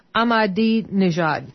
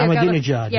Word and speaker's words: Ahmadinejad: His name is Mahmoud Ahmadinejad. – I think that's right. Yeah, I actually wrote it down here. – It is. I've done it phonetically. – Ahmadinejad.